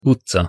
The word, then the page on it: utca: Hungarian